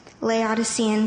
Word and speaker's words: Laodicean: By the way, it's Laodicean.